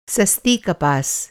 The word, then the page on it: कपास: کپاس